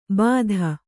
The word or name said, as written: ಬಾಧ